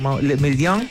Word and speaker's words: Le Méridien: Y te va a escuchar también nuestro siguiente invitado, y tiene que ver con Le Méridien. ¿Lo pronuncié bien? Más o menos. Le Méridien, por ahí, mejor.